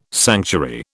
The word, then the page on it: sanctuary: British English